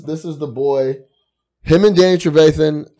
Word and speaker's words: Trevathan: Trevathan is straight savage. You said it right, like 100%. Roquan's obviously got the talent, but Trevathan has that.